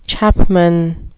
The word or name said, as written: chapman